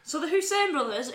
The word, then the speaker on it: Hoisin: Yeah, it's Hussein, but it's spelled Hoisin.